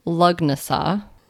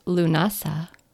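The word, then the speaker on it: Lughnasadh: It looks like Lugnasa, but it's Lunasa.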